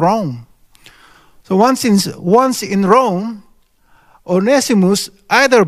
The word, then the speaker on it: Onesimus: A slave named Onesimus, some people say, read this as one Simus, but I asked Brother Otton, you know, our Greek scholar, says the correct pronunciation is Onesimus.